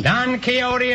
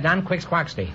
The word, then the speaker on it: Don Quixote: Don Quixote is right. Until I was 40 years old, I pronounced that Don Quix Quaxty.